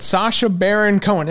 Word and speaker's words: Sacha Baron Cohen: But Sacha Baron Cohen, am I saying his name right? – Yep.